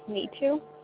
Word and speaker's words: Nitu: My name's Neetu. – Neetu? – Mm-hmm. – How do you spell that? – N-I-T-U.